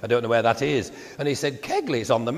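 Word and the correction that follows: Keighley: As it's spelt with a G on everybody's map, but you still pronounce it Keithley.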